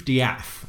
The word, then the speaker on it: Death: Well, my name is Keith Diaf. That's spelled D-E-A-T-H.